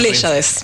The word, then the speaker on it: Pléyades: O sea, Pléyades. – Pléyades, sí, le vi el acento y no lo dije, brajo.